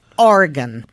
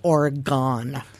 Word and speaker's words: Oregon: Oregon, also known as Oregon.